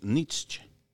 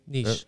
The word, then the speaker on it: Nietzsche: Entonces, por ejemplo, estaba leyendo, has oído hablar de el eh, maestro filósofo Friedrich Nietzsche. – a ver, lo pronuncié mal, yo creo, ¿no? – Nietzsche.